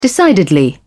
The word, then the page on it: decidedly: British English